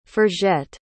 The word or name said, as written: forgette